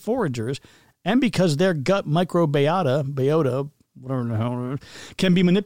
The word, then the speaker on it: microbiota: But I thought it would be like microbiota, but no, it's microbiota.